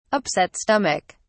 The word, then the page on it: upset stomach: British English